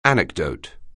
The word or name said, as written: anecdote